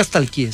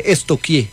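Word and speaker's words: stalkear: Porque no se dice nunca estalquié, se dice estoquié.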